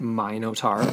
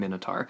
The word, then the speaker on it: Minotaur: Some pronounce it Minotaur because of its link to King Minos. We're going Minotaur.